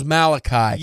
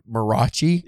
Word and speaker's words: Malachi: It was Malachi. – Yeah. – The whole time I read it, because I phonetically pronounced it Marachi.